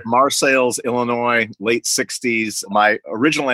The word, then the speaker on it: Marseilles: But that's just me in front of the ancestral Morello home in Marseilles, Illinois, spelled like Marseille, France, pronounced Marseilles because it's in central Illinois.